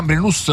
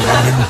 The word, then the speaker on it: Gambrinus: No, Gambrinus non si dice Gambrinus. – Vabbè, l'accento è Gambrinus.